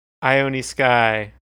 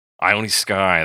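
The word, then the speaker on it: Ione Skye: Ione Sky. – Ione Sky, that is Donovan's daughter.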